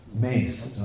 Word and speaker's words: mace: Mace. Okay, I'm sorry. Yeah, the pronunciation would actually be mace.